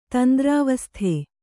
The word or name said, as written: ತಂದ್ರಾವಸ್ಥೆ